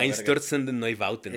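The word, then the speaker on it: Einstürzende Neubauten: Einstürzen den Neubauten.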